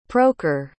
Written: prokhor

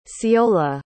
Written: ceola

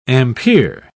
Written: ampere

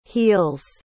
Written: heals

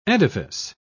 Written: edifice

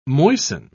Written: moisten

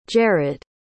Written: jared

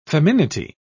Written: feminity

